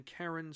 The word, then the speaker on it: Carron: Carron, Scotland? That's spelled C-A-R-R-O-N.